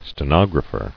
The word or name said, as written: Stenographer